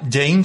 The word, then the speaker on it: Jain: Jane se llama, se escribe Jain, una. Jain.